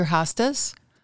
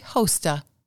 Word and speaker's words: hosta: "Why," I asked, "do most people pronounce the plant name hosta?" "It was named in honor of an Austrian botanist, Nicholas Thomas Host, long O."